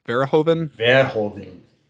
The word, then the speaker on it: Verhoeven: Paul Verhoeven? – Verhoeven.